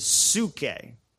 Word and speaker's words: psuche: The other term that you'll find life is psuche. If you were to spell it, P-S-U-C-H-E, psuche.